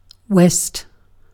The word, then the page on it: west: /wɛst/